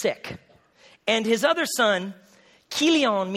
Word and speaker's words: Chilion: And the name of his wife was Naomi, and the name of his two sons was, Mahlon and Chilion, but it's actually pronounced Mahlon and Kilion. Just sounds better in Hebrew.